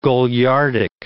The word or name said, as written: goliardic